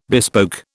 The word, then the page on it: bespoke: British English